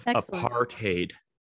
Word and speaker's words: apartheid: We have an official pronunciation of apartheid. – Apartheid. – Right? Apartheid. Yeah, because it's Dutch. There's a little Dutch inflection there.